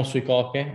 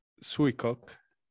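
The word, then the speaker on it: Suicoke: La giapponese però sarebbe Sui Coche. – Suikoke, sì, ma poi è una parola americana, quindi ti dirò. Ah, ok. Dobbiamo anche capire come pronunciano l'americano e i giapponesi, che è un po'... – Suikoke. – Sì, guarda, ognuno ha la sua pronuncia.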